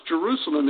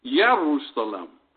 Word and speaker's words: Jerusalem: So Jerusalem was not pronounced Jerusalem, it was Yerusalem. The J has a Y sound.